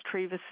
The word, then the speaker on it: Treva: It's Treva Seals. – Spell that for me. – T-R-E-V-A. Seals, S-E-A-L-S, like a Navy SEAL.